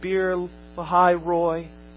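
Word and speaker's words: Beer-lahai-roi: "Therefore, the well that Hagar was sitting at, therefore the well was called Bir Lahai Roi," or pronounce it the way it looks, Bir Lahai Roi.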